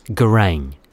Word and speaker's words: Garang: J-O-K. – And your first name, Mr. Jock? – It's Garang. G-A-R-A-N-G.